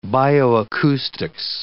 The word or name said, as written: bioacoustics